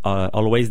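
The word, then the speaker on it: Alvvays: Donc le groupe Always disait récemment qu'on peut le prononcer comme on veut leur groupe, mais c'est drôle si on prononce les deux V tout seul. Donc il faut le prononcer comme un W.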